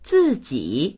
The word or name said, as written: zi4ji3